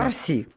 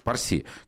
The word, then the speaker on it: Парси: извините за ударение, Трита Парси или Парси, не знаю, как правильно. – Парси. – Парси, который сказал, что арабские страны больше не будут единым целым.